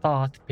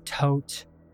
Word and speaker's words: Thoth: And I know that there's plenty of different ways to pronounce his name. Tot, Tot, Toth, like there's so many. When I was growing up, a little kid, reading about Egypt, researching it all on my own, because that's just the kind of kid I was, in my head it was pronounced Thoth. So whenever I mention him throughout this entire series, I'm going to say Thoth, because that's how I like to pronounce it.